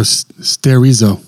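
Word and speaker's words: sterizo (στηρίζω): I'm going to go sterizo. – Sterizo. Yes. That's right. Yes, sterizo. It's where we get the word steroid from.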